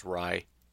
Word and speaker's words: Rye: But anyways, as I can gather without all the insights, because again, I didn't pay for it, basically, David Rye, I don't know if I said Ray before or what, I think it's Rye. Phonetically, it looks more like Ray to me, but it's Rye.